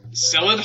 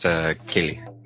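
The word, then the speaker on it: Ceilidh: Just to help you out. It's pronounced Kelly.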